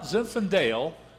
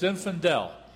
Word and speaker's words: Zinfandel: And let me tell you, it's not Zinfandel, it's Zinfandel. Even though it's an A-L, when you spell it, it doesn't pronounce like an E-L.